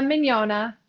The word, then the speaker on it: Mignogna: My name is Danielle Alora Mignogna.